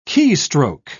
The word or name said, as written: keystroke